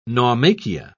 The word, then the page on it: naumachia: British English